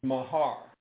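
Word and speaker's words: mahar (מהר): This word in Hebrew is mahar. Mahar, M-A-H-A-R. pronounced M-A-W-H-R.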